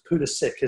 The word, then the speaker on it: Pulisic: In fact, it's Pulisic, isn't it? Because he's American. They mispronounce all their original names.